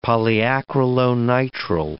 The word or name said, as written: polyacrylonitrile